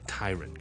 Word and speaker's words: tyrant: 咁啊，去到之后我讲 tyrant 呢个字咧，我就以为系读成 tyrant 喎。咁啊，其实咧系应该系 tyrant 嘅。